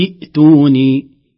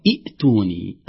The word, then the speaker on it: إيتوني: لو أحببنا أن نصل سيتغير نطق هذه الكلمة، سنقول في السماوات إيتوني، في السماوات إيتوني، تسقط همزة الوصل ويبقى لنا الهمزة الساكنة في أول كلمة همزة إيتوني، لكن إذا بدأنا لا نقول إيتوني أبدا،